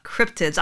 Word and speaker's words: cryptids: I used to think they were called cryptoids for the longest time. I've called them cryptoids. So they're like, oh, it's cryptids. I've been saying it wrong for 10 years.